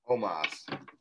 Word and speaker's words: Omos: Well, how do you pronounce it? Osmos, almost. – Omos.